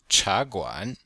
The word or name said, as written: cha2guan3